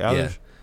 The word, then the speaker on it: Eilish: Is that how you say it, right? Eilish? – Yeah.